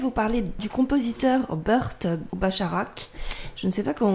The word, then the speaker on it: Bacharach: Donc alors, Bacharach. – Burt Bacharach. – Oh, yeah. – Bacharach.